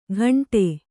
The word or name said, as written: ಘಂಟೆ